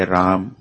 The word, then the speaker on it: Rama: And this question is from Pritesh Rati Prabhu regarding the pronunciation of Rama. Is it correct to just say Ram or Rama? – You don't get spanked if you say Ram.